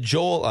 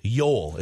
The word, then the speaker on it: Joel: God, you said Joel. I keep saying Joel. Yoel. It's Yoel. It's a soft J, right? – Yoel Eriksson Ek. – Yoel.